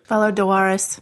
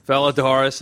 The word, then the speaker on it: Valar Dohaeris: Is that right? – Valar Dohaeris. – Valar Dohaeris. Thank you for the pronunciation check.